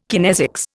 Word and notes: kinesics: Indian

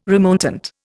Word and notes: remontant: Indian

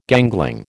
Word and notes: gangling: American English

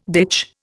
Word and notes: ditch: Indian

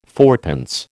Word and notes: fourpence: American English

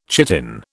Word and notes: chitin: British English

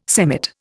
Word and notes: semmit: Indian

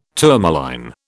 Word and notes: tourmaline: British English

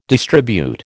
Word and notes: distribute: American English

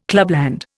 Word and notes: clubland: Indian

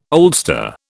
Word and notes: oldster: British English

